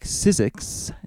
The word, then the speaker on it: SYZYGYX: I did SYZYGYX, Sizzix is the way it's pronounced.